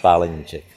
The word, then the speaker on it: Palenicek: In Czech, we pronounce it Tomáš Palenicek, but you did well.